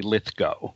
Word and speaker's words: Lithgow: Yeah, well, one good thing about this show is that it reminds us of the correct pronunciation of his last name, which I have had great difficulty remembering, even though he once told me personally. But it is, in fact, apparently Lithgow.